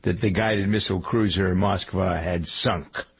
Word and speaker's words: Moskva: Also in league with number 1, the story of the conduct of the war, the Musk, is it, how do we pronounce it? The Moskva is the Russian flagship.